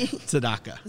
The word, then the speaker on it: Tzedakah: Tzedakah.